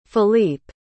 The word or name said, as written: felippe